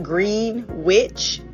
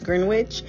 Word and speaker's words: Greenwich: I remember when Darren and I went to London, it's called Greenwich. It looks like Greenwich, but it's pronounced Greenwich.